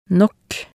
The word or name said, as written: nok